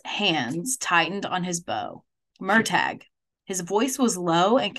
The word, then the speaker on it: Murtagh: Also, I looked in the pronunciation guide. It's the Murtagh.